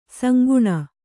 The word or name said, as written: ಸಂಗಿನಿ